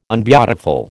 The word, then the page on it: unbeautiful: American English